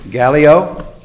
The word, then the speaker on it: Gallio: Gallio.